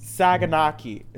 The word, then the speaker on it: Saganaki: B, Saganaki.